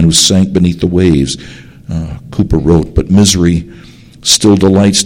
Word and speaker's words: Cowper: William Cooper, C-O-W-I-E-R, often mispronounced Cowper,